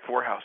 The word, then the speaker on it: Vorhaus: Vorhaus, yep.